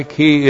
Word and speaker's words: ki: The conjunction ki. K long I ki.